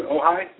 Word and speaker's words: Ojai: Is that, am I pronouncing that correct, Dr. Ojai? – Yes. Yeah, that's right.